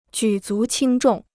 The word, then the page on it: 举足轻重: jǔ zú qīng zhòng